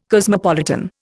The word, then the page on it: cosmopolitan: Indian